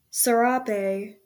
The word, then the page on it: serape: /sɛˈɹɑːpeɪ/; English